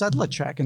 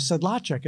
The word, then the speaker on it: Sedlacek: she tried to convince me that my name should be spelled or pronounced Sedlacek instead of Sedlacek.